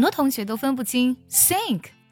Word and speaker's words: sink: 第一个单词 sink，这个单词呢是下沉的意思，拼作 S-I-N-K sink。这个单词我们在读的时候呢，字母 S 读作摩擦音，你在发音的时候呢，感觉气流呢从你的上齿和下齿中间的齿缝呢给呲出来那种感觉。